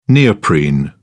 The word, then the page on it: neoprene: British English